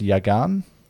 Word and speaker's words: Yagan: It's pronounced Yagan. – Yes, let's go with that.